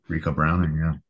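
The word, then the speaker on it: Ricou Browning: Rest in peace to Rico, or I don't know if I pronounced his name correctly. Rico Browning. – Rico Browning.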